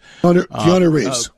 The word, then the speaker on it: Keanu Reeves: Canal Reeves, right? Am I saying his name right? Keanu Reeves? – Keanu Reeves.